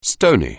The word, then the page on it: stony: anglais